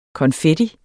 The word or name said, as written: konfetti